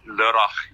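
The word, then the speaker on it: Lörrach: And are you based in Lorach, Germany? Is that, I'm probably butchering the name, but no, that's okay. I'm Australian. I butcher it all the time. Yeah, it's Lorach. Yeah, Germany. It's just Lorrach.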